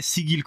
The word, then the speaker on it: Sigil: è Sigil, Sigil, sì, tecnicamente è Sigil con la G dura.